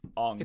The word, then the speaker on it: Aang: Is it Aang, like Aang or something? – Aang. – It's Aang.